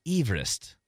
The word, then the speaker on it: Everest: The correct pronunciation of Mount Everest is Everest.